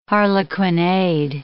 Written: harlequinade

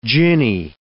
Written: ginny

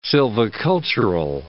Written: silvicultural

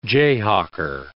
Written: jayhawker

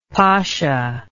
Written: pacha

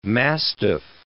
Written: mastiff

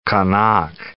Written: Qaanaaq

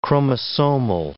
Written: chromosomal

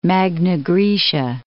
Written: Magna Graecia

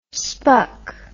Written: spuc

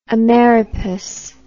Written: ameripass

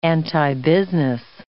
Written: antibusiness

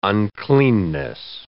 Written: uncleanness

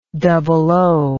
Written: double-O

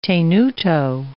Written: tenuto

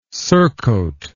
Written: surcoat

